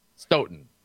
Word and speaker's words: Stoughton: I just, you know, Stout, you know, when in doubt, go to Stout. We're lacrosse guys. Okay. Stoughton, right? Stoughton. There you go. – Yeah, Plumber. Well, Stout doesn't have a GH in there.